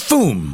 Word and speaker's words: Mfume: Mr. Fume? It's spelled M-F-U-M-E. – Oh, okay. He's from the Maryland area. I know who you're talking about. – Okay. Mr. Fume.